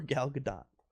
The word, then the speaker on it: Gadot: I'm not sure if it's Gal Gadot or Gal Gadot. – It's Gal Gadot. She's a Israeli. Hard T.